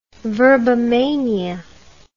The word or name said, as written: verbomania